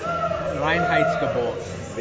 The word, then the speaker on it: Reinheitsgebot: Reinheitsgebot.